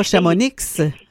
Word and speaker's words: Chamonix: Donc, effectivement, on prononce rarement le X. – On ne dit pas Chamonix.